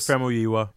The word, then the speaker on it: Famuyiwa: But yeah, Black Dynamite was directed by Scott Sanders, and also Dope was directed by Rick Famuyiwa.